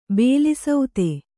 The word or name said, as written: ಬಲಸುತ್ತು